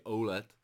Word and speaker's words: OLED: Já to vyslovuji anglicky, myslím, že pár lidí v komentářích to, takže OLED.